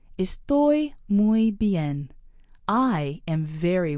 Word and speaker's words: estoy: Estoy means I am. Try it. – Estoy muy bien. Estoy muy bien. Estoy muy bien. – Notice the forceful sound on the last syllable of estoy.